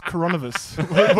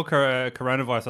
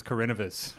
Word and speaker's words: coronavirus: This is, it's coronavirus. What'd you call it? – I call coronavirus. I thought it was Carinivus.